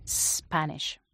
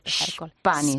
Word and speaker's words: Spanish: Y en inglés tendríamos que decir Spanish. ¿No? – Spanish. – Spanish. Y luego había gente que también ha pronunciado la S del final como una S española cuando es un sh, ¿no?